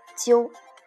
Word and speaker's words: 究: Um, however, if we add jiu with jiang, we should pronounce jiu uh, softly, which means you do not have a strong tongue on this word. jiu,